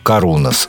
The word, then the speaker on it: Karunos: Группа «Карунас» с ударением на «у».